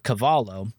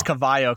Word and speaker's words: Cavallo: I just want to say it could be Cavallo. – I honestly think it's Cavallo, because Caballo, which is horse in Spanish, is spelled with a B instead of a V.